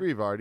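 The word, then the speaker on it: Greavard: G-R-E-A-V-A-R-D. I'm going to say Grievard. Meet Grievard. – Grievard, yeah.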